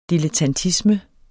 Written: dilettantisme

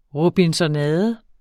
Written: robinsonade

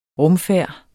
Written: rumfærd

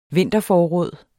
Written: vinterforråd

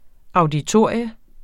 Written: AUDITORIERNES

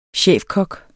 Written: chefkok

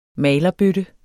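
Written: MALERBØTTER

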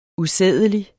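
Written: USÆDELIGT

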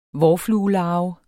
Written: vårfluelarve

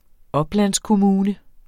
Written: oplandskommune

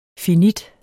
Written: finit

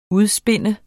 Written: udspinde

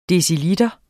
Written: deciliter

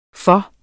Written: for

